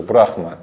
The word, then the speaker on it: Брахма: Вселенная называется яйцо Брахмы, потому что Брахма это через Хэ пишется, Брахма. Ну, Хэ почти не выговаривается, поэтому, если бы индусы наслышали, это был бы жуткий акцент, поэтому лучше просто не говорить, будет лучше.